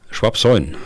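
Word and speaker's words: Schwabsoien: Schwabsäuen, ist das richtig? Weitgehend. ja, ja. Sagen Sie mal, wie es der Bayer macht. Schwabsäun.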